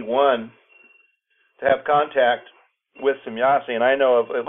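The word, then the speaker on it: Semjase: As a contactee, you had contact with Semyasi. Am I saying the name right? – Yeah, Semyasi. – Semyasi. – That's a German pronunciation.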